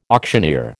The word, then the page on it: auctioneer: American English